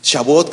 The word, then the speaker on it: Shavuot: Shavuot, que se escribe S-H-A. La B chica, la V, la U o T, Shavuot.